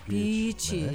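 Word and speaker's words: beach: 한 시간 정도만 달리면 만날 수 있는 보석 같은 그런 해변이 저는 비치 얘기할 때 발음이 걱정돼. 어. 비치 길게 늘려주면 돼요. – 비치 네.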